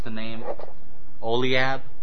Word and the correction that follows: Aholiab: was it the Oliab, the second in charge of the artisans? So he started to bring, say again? – Aholiab.